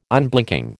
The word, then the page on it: Unblinking: American English